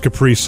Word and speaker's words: Caprese: You know, some people say Caprese. I've heard that before, really? – but it's Caprese salad.